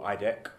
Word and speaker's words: IDEQ: So, my property company is called IDEQ or IDEC, as it's pronounced, the acronym.